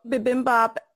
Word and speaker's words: bibimbap: So Michelle, bibimbap, am I pronouncing that correctly? – Yes.